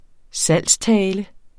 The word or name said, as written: salgstale